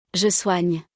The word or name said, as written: je soigne